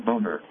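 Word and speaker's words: Boehner: John Boner.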